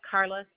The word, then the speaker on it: Carla: Yes, Carla, C-A-R-L-A.